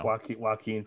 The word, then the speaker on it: Joaquin: Joaquin Phoenix. – That's the guy. Joaquin Phoenix and all those guys. – I know you can say it. It's wa.